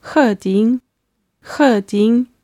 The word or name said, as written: heding